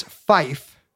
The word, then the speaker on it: Fife: You're not supposed to say three. You're supposed to say tree. Four is officially pronounced four in the International Radio Telephony Spelling Alphabet. It's two syllables. Five is fife.